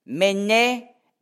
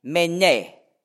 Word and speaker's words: mene: Mene, mene, tekel, ufasin. Ich habe jetzt also auch richtig betont, ja? Also mene ist endbetont. Mene, mene, tekel, die erste Silbe betont.